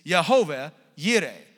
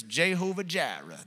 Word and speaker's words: Jehovah Jireh: Abraham's about to kill his son, God provides a substitutionary sacrifice, and in so doing, he establishes a name for himself, and the name is Yehovah Yireh, or if you're a Southern Pentecostal preacher, it's Jehovah Jireh, that one. Yay, there you go. Now let's go get some tacos. Like That's how they say it there.